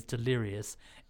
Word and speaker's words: Thelirious: And so it's literally just, if I come across them and I'm just like, so this time around I've got Thelirious. Sorry, their drag name is Delirious. It's spelled Thelirious.